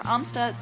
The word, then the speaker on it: Amstutz: All of my socials are at Sandra Amstutz.